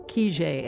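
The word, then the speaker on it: Kijé: Lieutenant Kijay, or Kijay as it's pronounced in Russian, was a book and then a movie about an army officer who never existed.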